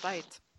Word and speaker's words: Streit: So, Strait? – Yay, Switzerland. – It's pronounced Streit in German.